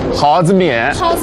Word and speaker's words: 蒿子面: 耗子面，本地人应该怎么读？ – 蒿子面，这个面是什么做的？